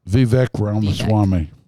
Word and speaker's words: Vivek Ramaswamy: Vivek Ramaswamy.